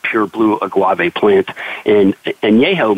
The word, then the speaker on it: Añejo: and it is Don Julio 1942 Añejo Tequila. – Añejo Tequila. Now, how would I spell it? – A-N-E-J-O.